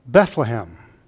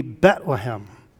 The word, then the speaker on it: Bethlehem: Most people would say Bethlehem, which is probably the right and correct pronunciation, but the Hebrews, the Jews, would drop the H on the first syllable. It would be Bethlehem, no H there.